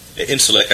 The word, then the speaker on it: Incirlik: Right now, for example, in Incirlik, military base, Incirlik, I guess is the proper way to pronounce it, in southern eastern Turkey, which is a major base for us to operate throughout the Middle East.